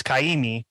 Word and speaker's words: Kaimi: Based off how his name is spelled, it would have an Okina after the A, so it's Kaimi, which is Hawaiian.